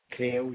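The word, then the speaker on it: Crail: It's Kale Medical Practice. – Kale? – Kale, yes.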